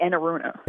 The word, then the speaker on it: Enaruna: It's actually Enaruna. – Enaruna.